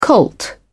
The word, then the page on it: colt: British English